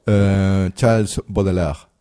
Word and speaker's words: Charles Baudelaire: Charles Baudelaire.